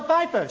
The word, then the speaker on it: Pfeiffer: Like Michelle Pfeiffer. She has a silent P.